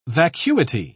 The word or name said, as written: vacuity